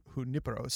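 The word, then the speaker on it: Junípero: Saint Junipero, Junipero. Sorry, there is an accent on the I. I failed to notice that.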